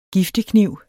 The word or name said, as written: giftekniv